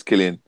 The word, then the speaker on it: Cillian: It's Cillian.